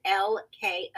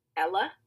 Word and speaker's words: LK: Yeah, so website and social media being Instagram and Twitter, is LK Events. And it is ELLEKAY and the word events with an S. I know a lot of people want to pronounce it Ella, but Ella is ELLA.